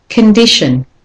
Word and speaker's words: condition: So the O in the first syllable is said as a schwa, con. And again, T-I-O-N is a common ending, so you have a schwa in there as well. Condition.